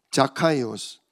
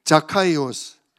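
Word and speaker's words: 자카이오스 (Ζακχαῖος): 이스라엘 나라 발음으로는, 이게 원래 성경에 그대로 써 있는 발음이에요. 자카이오스. 따라해 보세요. 자카이오스.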